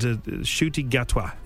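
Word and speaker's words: Ncuti Gatwa: And Shruti Gatwa is the correct pronunciation.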